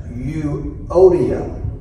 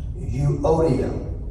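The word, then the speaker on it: Euodia: But the first one's name is pronounced Euodium. Euodium.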